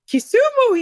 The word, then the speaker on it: Kisumu: Kisumu, yes.